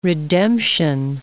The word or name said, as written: redemption